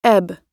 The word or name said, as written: ebb